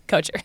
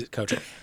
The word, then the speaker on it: Kocher: And then Kocher, fun thing is that his dad does pronounce it Cocker. And he was like, please don't do it. Yeah, and he's a great stand-up bit about it. So he is Kocher.